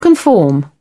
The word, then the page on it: conform: British English